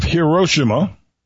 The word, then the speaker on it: Hiroshima: It has been reported that some residents of Hiroshima is the real way you pronounce it, discovered that their bedroom closet was a boom closet.